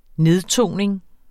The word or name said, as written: nedtoning